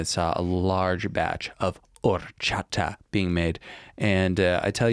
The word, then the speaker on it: horchata: Quick pronunciation lesson. It's horchata. – Horchata. – Closer. Horchata.